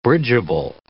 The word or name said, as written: bridgeable